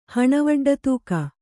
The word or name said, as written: ಹಣವಡ್ಡತೂಕ